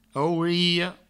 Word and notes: aurelha: aureio; auriho; Provençal

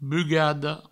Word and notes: bugada: bugado

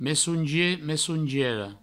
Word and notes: messongier: messongiera; messoungie; messoungiero

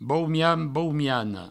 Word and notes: boumian, boumiana: boumian, boumiano

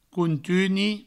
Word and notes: contuni: countuni

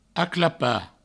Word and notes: aclapar: aclapa

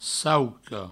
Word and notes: sauca: sauco

